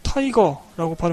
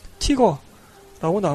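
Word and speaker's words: Tiger: 한 가지 재밌던 것은 영화 내에서 독일 티이거 전차를 영어식으로 타이거라고 발음하는데 자막에는 티거라고 나온 것.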